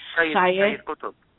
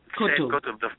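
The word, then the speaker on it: Sayyid Qutb: Sayed Qutb. Qutb, the father of terrorism.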